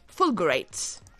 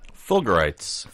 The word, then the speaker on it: fulgurites: They call it fulgurites. – Fulgurites. – Fulgurites. – How do you spell that? – That's F-U-L-G-U-R-I-T-E-S.